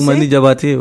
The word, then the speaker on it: Toumani Diabaté: Toumani Diabaté, c'est ça J'ai bien prononcé Toumani Diabaté, oui, c'est, l'un des plus grands griots aujourd'hui dans le monde.